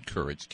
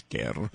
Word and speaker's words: Kerr: Stan Courage, K-E-R-R. – Unless you're Scottish, in which case it's Kerr. – Oh, I didn't know that. – Yeah, you got to roll your R's.